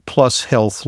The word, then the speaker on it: Plus HLTH: Today we're going to talk about the new Plus Health line by CV Sciences. It's spelled Plus HLTH.